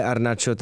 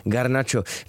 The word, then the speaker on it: Garnacho: Tak to je jenom takový šotek, co se ano, a taky vlastně, kdybyste někdy slyšeli, že ho někdo vyslovuje Arnacho, tak on sám se takhle představuje, ale vzhledem k tomu, aby byli diváci, fanoušci a posluchači spokojení, tak ho říkáme Garnacho.